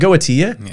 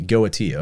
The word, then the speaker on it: Goetia: Is it Goetia? – Yes. – I always pronounce it with the soft T. Goetia. Okay. Yeah. That's the official pronunciation.